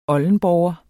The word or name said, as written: oldenborger